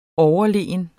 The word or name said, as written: overlegen